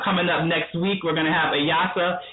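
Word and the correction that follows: Ilyasah: Ilyasa. I want to make sure I keep pronouncing her name correctly.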